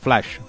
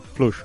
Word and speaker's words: flush: O flash, flush, yo creo que se pronuncia. Flush, tener flush. Sí, sí, ibas bien, ibas bien.